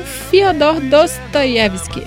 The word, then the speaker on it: Fyodor Dostoyevsky: bebiam chá e liam Fyodor Dostoyevsky.